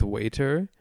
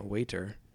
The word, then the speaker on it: Wiatr: last name is W-I-A-T-R. However you want to pronounce that. Waiter. – Okay. W-I-A-T-R? – Yeah, no vowel between the T and the R.